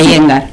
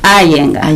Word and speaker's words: Iyengar: ¿Cómo es que se pronuncia? – Iyengar. – Iyengar, así aprendo. – Se escribe Iyengar. – Y yo digo así, pero cero, es... – Pero se pronuncia Iyengar.